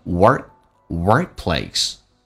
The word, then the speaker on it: Workplace: Workplace gossip Work 的 K 可是不用发音，在美式发音经常两个辅音在一起的话，那么前面一个辅音是不发音，如果前面是元音，气流一般要阻塞一下，变成 Workplace